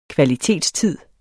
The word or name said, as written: kvalitetstid